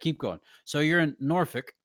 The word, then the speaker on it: Norfolk: And wait. Norfolk? – Norfolk. – Okay, got it. Nice. I'm not writing that in the show notes. – No. That's how it's pronounced though. – Yep. – That's how it's pronounced. – Oh, bless. – It is pronounced Norfolk.